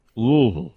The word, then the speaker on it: Louisville: Louisville. You kind of softly hit the L, and then the rest of it just melts in your mouth. Louisville. Everything just runs together. Louisville. – Louisville.